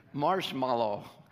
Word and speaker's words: marshmallow: How is it spelled? Yeah, there's no E there. It's marshmallow.